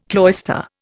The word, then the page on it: cloister: Indian